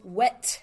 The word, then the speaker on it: Wit: Well, actually, Sean, it's Oh Wit a Night. Oh Wit a Night, as in wits her face.